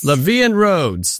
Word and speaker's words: La Vie en Rose: La Vie en Rose? – La Vie en Rose.